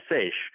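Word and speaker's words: seiche: I say seish. How about you? – Talk to 10 different people and you'll hear 10 different pronunciations of that word. We usually call it seish.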